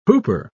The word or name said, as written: hooper